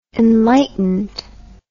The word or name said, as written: enlightenedness